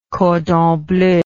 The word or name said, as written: cordon bleu